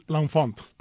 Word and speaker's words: L'Enfant: L'Enfant.